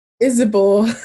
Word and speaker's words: Isibor: Did I say that correctly? – Isibor. Nearly there.